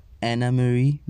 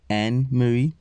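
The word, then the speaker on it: Anne-Marie: I said Anna Marie, but it's Anne Marie.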